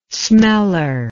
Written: smeller

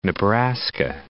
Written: Nebraska

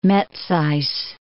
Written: Metsys

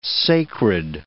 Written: sacred

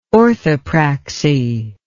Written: orthopraxy

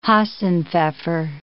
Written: hasenpfeffer

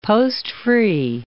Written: post-free

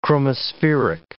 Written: chromospheric

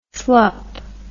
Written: slup

